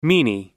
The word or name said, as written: meany